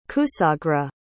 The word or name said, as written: kusagra